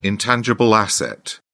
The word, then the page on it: intangible asset: British English